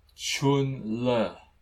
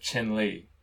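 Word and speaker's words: Chenle: Okay, yes, yeah, see, I read your comments, and also it's Chun-Le instead of Chen-Li, right? Chun-Le. Chen-Le, yeah, I said it with the accent.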